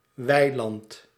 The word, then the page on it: pré: [pʁe]; France